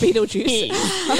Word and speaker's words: Betelgeuse: Betelgeuse. – Betelgeuse. – Betelgeuse. – But it's pronounced Beetlejuice.